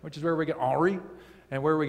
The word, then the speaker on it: Henri: And did you know that Henry, the English name Henry, is actually a play off the French name Henri. Oh, sorry, I didn't say that right. Henri. There we go.